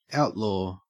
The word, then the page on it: outlaw: Australian English